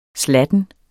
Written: slatten

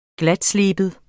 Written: glatsleben

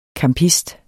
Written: CAMPISTERNE

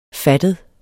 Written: fattet

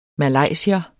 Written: malaysier

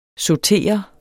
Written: sautere

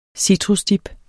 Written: citrusdip

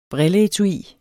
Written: brilleetui

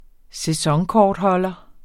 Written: sæsonkortholder

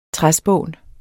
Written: træspån

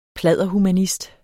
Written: pladderhumanist

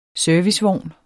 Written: servicevogn

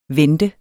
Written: vente

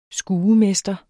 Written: skuemester

